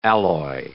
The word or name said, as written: alloy